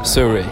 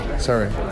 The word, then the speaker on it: Surrey: Surrey. Surrey. Surrey.